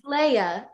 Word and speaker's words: Laya: It's Leia, like Star Wars.